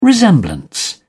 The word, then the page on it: resemblance: British English